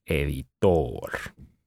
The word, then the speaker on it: editor: Editor. The stress is on the last syllable. Editor.